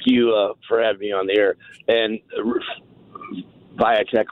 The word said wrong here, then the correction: Vivek: whatever you correctly pronounce his last name, I just heard your comments. That is bizarre. That's beyond being a charlatan. – Oh, Vivek. – That was Vivek, whatever.